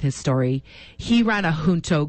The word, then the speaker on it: Junto: And so, and then the word Junto, it's spelled J-U-N-T-O. So people often say it's Junto, but it's a Spanish word.